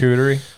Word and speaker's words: charcuterie: I can never say that right. Forgive me. – Charcuterie.